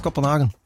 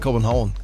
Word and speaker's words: Kopenhagen: Lucas Graham aus Kopenhagen. Second Chance. – Total falsch ausgesprochen, es war holländisch ausgesprochen. Ne? Das heißt, glaube ich, Kopenhagen.